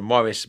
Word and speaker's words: Maurice: I think Americans normally pronounce the name Maurice, but I'm sorry, I can't do that. So Maurice Baring, B-A-R-I-N-G.